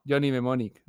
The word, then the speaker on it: Johnny Mnemonic: Johnny Mnemonic.